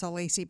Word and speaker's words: Alacy: And because most people don't know how to spell my last name or pronounce it, it's Alacy, by the way.